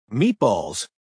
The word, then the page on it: meatballs: American English